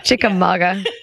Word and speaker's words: Chickamauga: Chickamauga.